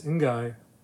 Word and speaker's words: Ngai: I actually looked up how to say his name. And it's Ngai. – Yeah. N-G-A-I is Ngai.